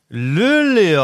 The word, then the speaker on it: Luleå: Ja, wir hätten, es gibt ja so viele schöne Städte in Schweden, ich würde sagen Luleå. – Luleå, auch sehr gut ausgesprochen. Luleå ist tatsächlich korrekt, 2015 das Ding gewonnen gegen Frölunda und dann waren sie 2023 auch nochmal im Finale gegen Tappara Tampere und haben da verloren.